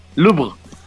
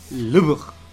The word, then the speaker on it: Louvre: Ah, el Louvre. ¿Cómo? Louvre. Louvre.